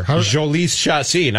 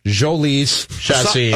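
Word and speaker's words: Jhoulys Chacín: People thought it was Jolice. It's Jolice. It's a soft J. Jolice Chacin.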